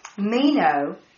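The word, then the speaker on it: meno (μένω): And then it comes also from, well, 303 comes from 3306, meno, M-E-N, emphasis on the M-E-N, hyphen O, which means to stay in a given place.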